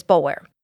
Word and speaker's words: Boulware: You will also hear the name Boulware, which is pronounced differently in the Lowcountry. This family is from the Columbia area, so we're going to pronounce it the way that it's spelled, which is Boulware.